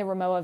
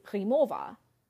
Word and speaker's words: Rimowa: So I said Ramoa, despite the fact that I do speak German, which, so the typical pronunciation of Ramoa in the German language be Rimowa.